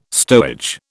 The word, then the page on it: stowage: British English